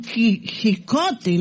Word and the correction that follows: Quijote: No, por favor, no se dice don Jicote, se dice don Quijote de la Mancha, la obra del ilustre don Miguel de Cervantes.